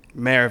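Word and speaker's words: Mare: But like, I watched Mar of Easttown. How do you say Mar of Easttown? – Mayor of Easttown. – Mayor. – Mayor.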